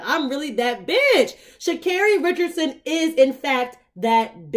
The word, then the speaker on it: Sha'Carri: And it's Sha'Carri, not Sha'Carri.